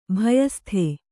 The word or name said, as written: ಭಯಸ್ಥ